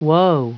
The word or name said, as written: woe